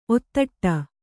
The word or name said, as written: ಒತ್ತಡೆ